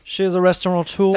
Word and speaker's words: restaurateur: How do you say that? – Restaurateur. – But I hear an N in there. – Well, I mean, I think it's... – It's not spelled with an N.